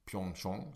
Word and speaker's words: Pyeongchang: I det som heter Sweden Arena, som är ett så Sverigehus som de har i Pyeongchang, som jag lärt mig att det ska uttalas.